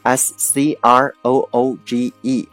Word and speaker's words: Scrooge: S C R O O G E 这个词啊，它就是一个表示吝啬鬼的意思啊，读成 Scrooge，Scrooge。 OK，S C R O O G E，Scrooge 就是名词，吝啬鬼或者叫做守财奴的意思。 OK，我们来看一个例句：If you call someone a Scrooge, you disapprove them because they are very mean and hate spending money.